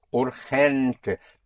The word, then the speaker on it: urgente: And in Spanish, the G before E and I is pronounced like in Scotland, the Loch Ness. Loch. Like it's. So urgent would be urgente. Urgente. But spelt with a G.